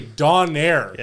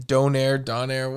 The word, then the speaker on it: Donair: You shouldn't have to be convinced to say it right. It's Donair. It's a Donair. – Fine. Get a Donair. – No, you still said it wrong.